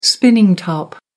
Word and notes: spinning top: American English

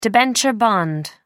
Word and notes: debenture bond: American English

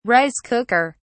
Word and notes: rice cooker: British English

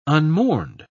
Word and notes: unmourned: British English

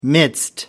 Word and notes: midst: American English

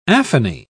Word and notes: aphony: British English

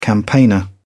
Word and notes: campaigner: British English

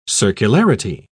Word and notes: circularity: British English

